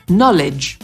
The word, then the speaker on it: knowledge: Natomiast początek słowa wiedza to nie know, tylko no. Wiedza to knowledge.